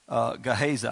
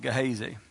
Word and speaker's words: Gehazi: The same leprosy that was on Nahum came on this servant, Gehazi. I said it wrong, but Gehazi.